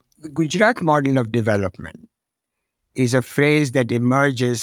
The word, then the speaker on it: Gujarat: And he developed, when he was governor of the state of Gujarat, if I've pronounced that correctly, or close to it. – Yes, that's Gujarat is very good.